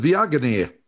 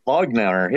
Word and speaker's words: Viognier: Oh, I know how to pronounce it. Viognier. – Viognier, yes.